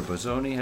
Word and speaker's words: Bosoni: My guest today is Debora Bosoni. How do you pronounce it? Bosoni? – Bosoni, yes, it's correct.